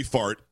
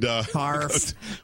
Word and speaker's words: Harf: A lot of people want to be heard on Marie Fart and Harf.